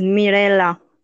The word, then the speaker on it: Mirella: So my name is Mirella. – Mirella. – With a soft Mirella. L. With a soft L.